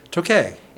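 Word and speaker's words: Toquet: So how do you say Tokay? – Tokay. – And it's T-O-Q-U-E-T.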